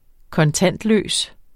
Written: kontantløs

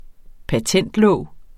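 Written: patentlåg

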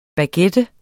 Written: baguette

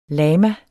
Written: lama

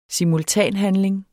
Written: simultanhandling

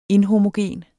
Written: INHOMOGENT

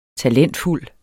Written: talentfuld

